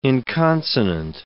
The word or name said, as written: inconsonant